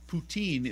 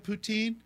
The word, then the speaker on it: poutine: But they eat that stuff, and it's so funny because it's roughly pronounced as the same pronunciation of the Russian leader. So have you ever had poutine? – No, I've never heard of it.